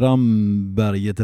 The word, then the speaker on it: Ramberget: Så att Ramneberget eller Ramberget, Ravnberget är gammalt namn på det här. Och oavsett vilken gammal namnvariant man hittar då, så är det ju kort vokal. Det är så det alltid har uttalats.